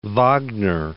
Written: Wagner

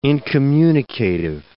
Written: incommunicative

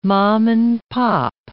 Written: mom-and-pop

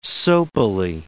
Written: soapily

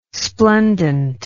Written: splendent

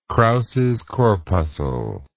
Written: krauses corpuscle

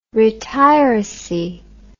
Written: retiracy

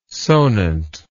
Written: sonant

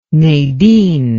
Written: Nadine

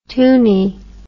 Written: tuny

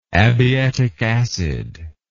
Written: abietic acid

